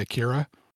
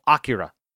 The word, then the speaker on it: Akira: Just so you know, I pronounce it the American way, Akira. – I know. That's not the way it's pronounced because it's from Japan. It's called Akira.